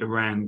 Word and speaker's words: Iran: They're interviewing the American centre midfielder Adams before the Iran game. And his first thing, he said, well, I have two things. First one's not really a question. It's just a point. Our country is pronounced Iran, not Iran.